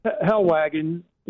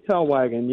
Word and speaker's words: Hellwagon: Hellwagon, yeah. German Hellwagon, yeah. – Hellwagon, right? – Hellwagon, that's it.